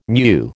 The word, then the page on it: mu: American English